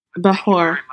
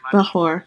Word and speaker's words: Bechor: Bechor. Bechor. – And I would like to... Bechor. – B-E-C-H-O-R.